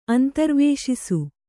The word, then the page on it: ಅಂತರ್ವೇಶಿಸು: antarvēśisu